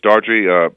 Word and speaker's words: Daughtry: Seal, Yolanda Adams, yes, Chris Daughtry, I think I said his name right. – Yeah, you did.